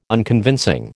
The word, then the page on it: Unconvincing: American English